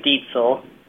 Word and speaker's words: Deitzel: It's an interesting spelling, D-E-I-T-Z-E-L. – Well, it's pretty close. It's actually Dietzel, but Diesel's pretty close there.